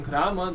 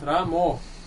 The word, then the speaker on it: Rama: Sometimes devotees, instead of chanting Rama, they chant Ramo. – Yeah, there is Bengali accent.